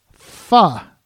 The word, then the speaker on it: Pho: But we did Pho.